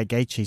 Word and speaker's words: Gaethje: Yeah, Gaethje.